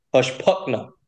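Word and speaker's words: Hushpuckena: So the correct pronunciation is Hushpuckina. – So the E is just silent almost.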